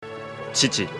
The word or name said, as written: Chichiri